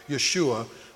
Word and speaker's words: Yeshua: There were a lot of kids running around with the name of Joshua, or Yeshua was the Aramaic pronunciation.